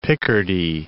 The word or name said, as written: Picardy